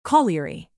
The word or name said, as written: colliery